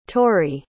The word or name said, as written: torrie